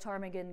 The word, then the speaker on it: ptarmigan: P-T-E-R-O-N. And I'd like to ask you, as an audience, why you can't hear a ptarmigan go to the bathroom. Because the P is silent.